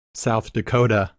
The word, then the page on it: South Dakota: English